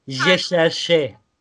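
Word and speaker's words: J'ai cherché: Çok kısa şarkı nasıl telaffuz edildiğini söyleyeyim. Jeşerşe. Aradım Jeşerşe. diyor.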